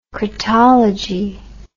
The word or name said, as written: cratology